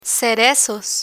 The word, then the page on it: cerezos: spanish